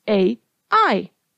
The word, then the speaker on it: Ai: It's spelled with two letters A I.